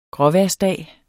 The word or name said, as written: gråvejrsdag